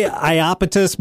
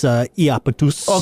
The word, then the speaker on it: Iapetus: Yeah, it's some people say Iapetus, but if you want to pronounce it the Greek way, it's Iapetus.